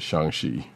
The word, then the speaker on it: Shang-Chi: But yeah, as Agent 70 has reminded me recently on Twitter, it is pronounced Shang-Chi.